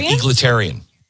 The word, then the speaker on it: egalitarian: But he was also an elitist, and real key here, egalitarian. How do you say this word? I sound like such a moron all the time. – Egalitarian. – Yes, egalitarian. Thank you.